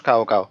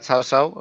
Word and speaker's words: Cao Cao: A ver, yo creo que lo más fácil es que lo llamemos cao cao porque aunque es verdad que el nombre mucho más cercano sería Chao Chao eh, más cercano a la pronunciación china, hace eh, a cabo eh, mucha gente que no sepa chino pero lo conozca por los videojuegos o cosas así, lo leerá como venga en pinyin, así que casi mejor lo decimos igual y así todo el mundo lo podrá saberlo, ¿no?